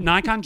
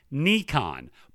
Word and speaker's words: Nikon: I heard that Nikon Japan said the correct way is, phonetically spelling here, Nikon, but they are also happy for people to say Nikon in third place or Nikon, rhyming with kick on.